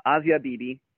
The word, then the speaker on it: Asiya Bibi: So, yeah, very, very close to the correct pronunciation. I was actually corrected on it not too long ago myself. Asiya Bibi,